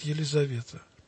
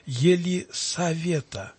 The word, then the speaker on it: Елисавета: Нельзя писать Елизавета. Надо писать Елисавета. Не З, а С. потому что это имя произносится в церкви не через букву «З», а через «С».